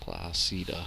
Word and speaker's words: Placida: Moving on to, how do you say this? Placida? – Yeah. Placida.